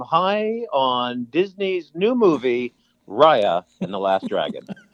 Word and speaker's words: Raya: So Raya is how you pronounce it.